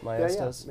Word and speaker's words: Maestas: Am I saying that right? Maestas. Yeah, yeah. yeah, it's, yeah. it's Hispanic, so Maestas.